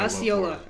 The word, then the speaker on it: Osceola: Osceola? – Oh, is that how you say it? See, Mr. Florida man over here, he knows. – I love Florida. – Osceola.